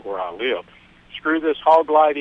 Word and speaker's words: Hawglite: But it's spelled H-A-W-G-L-I-T-E, hog light.